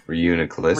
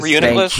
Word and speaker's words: Reuniclus: Reuniclus. – Reuniclus.